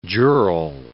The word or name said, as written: jural